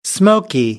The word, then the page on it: smoky: American English